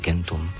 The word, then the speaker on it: centum: Tieto indoeurópske jazyky sa delia na skupinu, poviem to veľmi laicky, kentum, podľa číslovky 100, latinský kentum. – Centum, ale stará výslovnosť bola skáčkom, čiže tam je tá hrubá zadná samohláska.